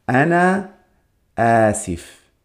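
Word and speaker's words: Ana Asif (أنا آسف): Así que vamos a ver, en masculino era Ana Asif.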